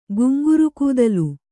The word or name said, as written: ಗುಂಗುರುಕೂದಲು